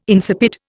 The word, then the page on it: insipid: Indian